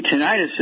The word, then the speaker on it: tinnitus: Dr. Wallen, I have been challenged with tinnitus, is how you pronounce it, where the ring in the ears, you know, it's on your ear. – Yes, tinnitus, sir.